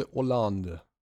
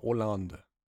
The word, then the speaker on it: Hollande: Auch von dem Aussprachegedöns hier sagen sie Hollande. – Also whatever. – Ohne das H, genau. François Hollande.